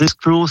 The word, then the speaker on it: Esclos: Allez, vous êtes le président des Esclopes, je le prononce comme ça, des Esclopes Saint-Pérolais. Ça veut dire, vous m'avez dit Je rectifie, les Esclos Saint-Pérolais.